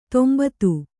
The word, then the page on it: ತಂಬತ್ತಿ: Kannada